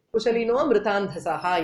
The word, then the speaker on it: कुशलिनो मृतान्धसः: तत्र लेखनसन्दर्भे वयं कुशलिनः कुशलिनो मृतान्धसः इति यथा लिखामः तदा अवग्रहचिह्नेन तत्र अकारस्य उपस्थितिं वयं बोधयामः, किन्तु यदा उच्चारणसन्दर्भे तु अकारस्य उच्चारणं तु न श्रूयते कुशलिनो मृतान्धसः इति.